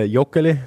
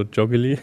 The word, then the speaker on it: Joggeli: Ähm, ich hätte es jetzt nicht so ausgesprochen, eher so Joggeli.